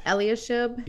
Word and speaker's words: Eliashib: Eliashib? – Yep. – Eliashib, I don't know.